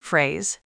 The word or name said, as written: phrase